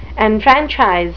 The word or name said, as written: enfranchise